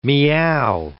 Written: meow

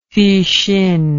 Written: fuxin

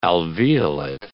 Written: alveolate

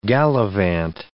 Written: gallivant

